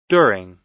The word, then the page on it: during: American English